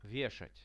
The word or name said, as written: veshat